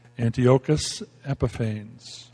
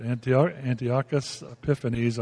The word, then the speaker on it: Antiochus Epiphanes: Now Judea was under the rule of, and this is, the name is pronounced many different ways, Antiochus Epiphanes, or Epiphanes. That's it. Antiochus Epiphanes, and it's pronounced, and everybody messes it up, so don't hurt me.